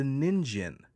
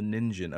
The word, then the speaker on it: Ningen: And I discovered this weird thing that's kind of a somewhat more modern day discovered cryptid called the Ninjin. Have you ever heard of that at all? – Nope. – The Ninjin. Okay. So it's spelled Ningen.